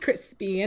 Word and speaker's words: Chrispy: And my name is Crispy. And that's spelled C-H-R-I-S-P-Y.